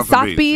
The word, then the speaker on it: Sotheby's: Yeah, Sotheby's.